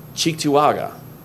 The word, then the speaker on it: Cheektowaga: And Thursday, Cheektowaga.